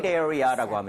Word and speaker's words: separate: So, separate Separate. – area라고 하면 틀리고요. Separate area, separate area라고 하셔야 돼요.